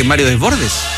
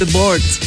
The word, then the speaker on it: Desbordes: Oye, si René Descartes se pronunciaba René Descartes, Mario Desbordes debería ser Mario Desbordes. – Desbordes.